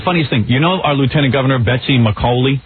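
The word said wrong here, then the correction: McCaughey: McCoy. – Whatever. Yeah. No one can say her name because it's spelled McCaffey.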